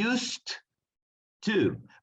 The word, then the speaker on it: used to: Y ponemos una T al final, to be used to. Pero como pegamos ese used y to, no suena como una sola T.